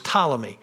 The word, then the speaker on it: Ptolemy: And by the way, when you see that word, the P is silent on it. It's Ptolemy.